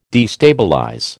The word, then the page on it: destabilize: American English